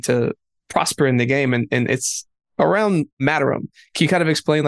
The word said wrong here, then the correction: Materium: It's pronounced Materium.